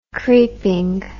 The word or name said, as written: creeping